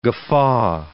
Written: guffaw

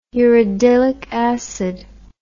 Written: uridylic acid

